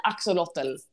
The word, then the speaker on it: axolotl: Ax, o, lot, ull. Jag tänker att jag säger axolotl.